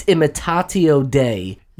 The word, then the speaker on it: imitatio Dei: I believe it's imitatio Dei. – You nailed it.